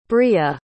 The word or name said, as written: bria